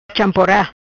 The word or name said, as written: Champora